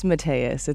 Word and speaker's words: Mateus: Say your last name so I don't mess it up. – It's Mateus. It's okay.